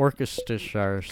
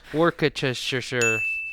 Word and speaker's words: Worcestershire: Worcestershire sauce. – Good job. Yeah. Worcestershire.